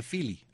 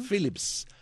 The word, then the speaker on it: Phillips: Pero yo decía Philly. – Pero si tú no sabías ni dónde estaba el papel, hijo. – Phillips.